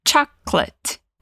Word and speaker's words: chocolate: Reparou bem na pronúncia de chocolate? Se escreve igualzinho em português, mas se fala bem diferente. Olha só, em português a gente tem quatro sílabas: chocolate. Em inglês são duas: chocolate.